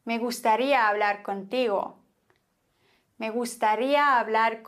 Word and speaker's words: gustaría: Me gustaría hablar contigo. Wieso? Naja, zunächst mal müssen wir immer die Silbe betonen, auf der ein Akzent liegt. Bei gustaría ist es deswegen klar auf dem I. Me gustaría. Bei hablar haben wir dann die Regel,